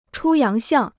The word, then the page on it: 出洋相: chū yáng xiàng